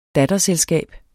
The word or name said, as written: datterselskab